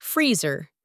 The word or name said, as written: freezer